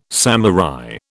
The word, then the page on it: samurai: British English